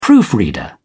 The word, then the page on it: proofreader: British English